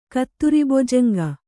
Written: ಕತ್ತುರಿಬೊಜಂಗ

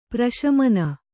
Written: ಪರಿಶಮನ

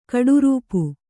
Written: ಕಟ್ಟಾರ್ಪು

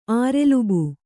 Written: ಆರೆಲುಬು

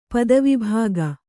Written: ಪದವಿಭಾಗ

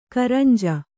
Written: ಕುರಂಜಿ

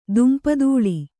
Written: ದುಂಪಾದೂಳಿ

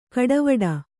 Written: ಕಡೆವೀಡು

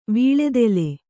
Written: ವಿಳ್ಳೇದೆಲೆ

